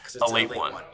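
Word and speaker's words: Ale-8-One: It's supposed to be pronounced a late one.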